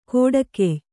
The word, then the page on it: ಕೊಡಗೆಯ್: koḍagey